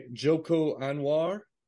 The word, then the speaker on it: Joko Anwar: Directed by Joko Anwar. I'm sure I'm pronouncing that terribly. – Oh, I am sorry. Joko Anwar. – No, that's, right.